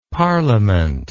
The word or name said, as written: parlement